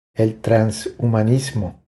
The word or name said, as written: El transhumanismo